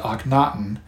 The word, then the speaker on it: Akhenaten: It weighed so heavily on his mind that, in the fifth year of his reign, he changed his royal name to Akhenaten, and that is spelled A-K-H-E-N-A-T-E-N.